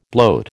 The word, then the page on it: bloat: American English